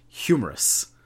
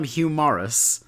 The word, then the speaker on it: Hugh Morris: So the commentary pronounces his name humorous every time. They don't call him Hugh Morris. They call him humorous